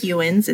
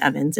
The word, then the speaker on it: Ewens: It looks like Ewens. It's Stephanie, E-W-E-N-S. But it's pronounced Evans. It's weird, I know.